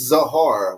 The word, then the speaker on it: Zahara: It's Zahara with a Z, but like Sahara.